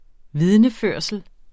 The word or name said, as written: vidneførsel